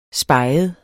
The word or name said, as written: speget